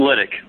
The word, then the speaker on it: Lyt1c: I'm Lytic.